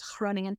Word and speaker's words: Groningen: They do a hard G, is Groningen.